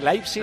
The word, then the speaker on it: Leipzig: Se escribe Leipzig, pero hemos aprendido a decir Leipzig, Leipzig todos muy bien.